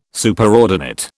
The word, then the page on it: Superordinate: British English